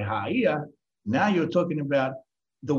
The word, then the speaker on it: Gaia: It's not Gaia, it's Haia, because you pronounce the G in the old language as an H.